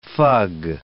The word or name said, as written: fug